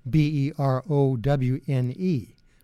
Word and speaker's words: Berowne: Bacon turns around and he anglicizes it and spells it B E R O W N E, Berowne.